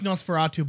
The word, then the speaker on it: NOS4A2: It's pronounced Nosferatu, but it reads like a license plate, so it's NOS4A2.